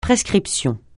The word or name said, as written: prescription